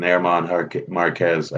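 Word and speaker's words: Germán Márquez: I do have interest in Herman Marquez. I believe is the right way to say his name because I've been yelled at repeatedly over the last year about it.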